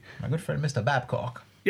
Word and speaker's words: Babcock: Perhaps pronounced Bacco. – Bacco. – But no, it's Babcock.